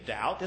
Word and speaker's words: doubt: I said, there's no B in doubt. It's not Doubt. I made her doubt herself. And it's true that we don't pronounce the B, but the reason that there's a B in there is that it comes from a Latin word, dubiter, which means double-minded.